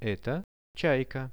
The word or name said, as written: eto chaika